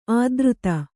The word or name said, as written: ಅಧರಿತ